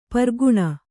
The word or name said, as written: ಪೀರಖಾನಿ